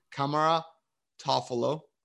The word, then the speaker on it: Kamara Toffolo: Did I say it right? Because I've been listening to it like 104 times. – Yeah. Kamara Toffolo. Yeah. So, yeah, Toffolo. It is tough, isn't it? Yeah. Kamara, like Tamara, or like Kamaro Toffolo.